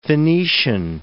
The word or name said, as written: Phoenician